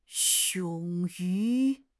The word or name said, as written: 勇于